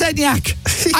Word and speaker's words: Enyaq: It is Enyaq. I know it's Enyaq.